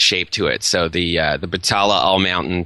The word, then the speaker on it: Batalla: Batalla is how it's pronounced, B-A-T-A-L-L-A.